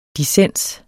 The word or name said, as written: dissens